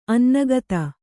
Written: ಅನ್ನಗತ

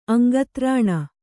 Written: ಅಂಗತ್ರಾಣ

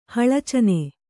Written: ಹಳಚನೆ

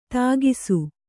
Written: ಥಕಾಸು

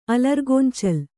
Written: ಅಲರ್ಗೊಂಚಲ್

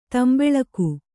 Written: ತಾಂಬೂಲಿಗ

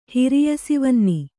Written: ಹಿರಿಯಸಿವನ್ನಿ

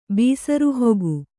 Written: ಬೀಸರುಹೊಗು

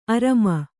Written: ಅರಮ

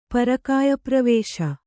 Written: ಪರಕಾಯಪ್ರವೇಶ

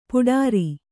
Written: ಪೌಡರು

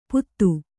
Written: ಪುದು

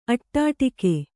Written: ಅಡ್ಡುಗೆ

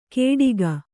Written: ಕೇಡಿಗ

